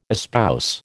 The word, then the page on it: espouse: American English